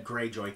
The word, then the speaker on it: Greyjoy: So I know the last name Greyjoy and Targaryen. I don't know if I'm saying that correctly. – You have said Greyjoy correctly.